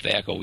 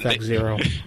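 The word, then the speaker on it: THAC0: You guys call it Thaco. We used to call it Thaco. – Well, we actually call it Thac Zero.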